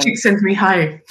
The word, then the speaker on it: Csikszentmihalyi: Csikszentmihalyi.